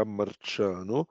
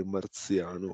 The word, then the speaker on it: Marciano: probabilmente ormai si pronuncerà Marciano, ma essendo un ebreo di origini da ebrei sefarditi, discendenti da spagnoli, potrebbe quasi essere marziano o qualcosa del genere.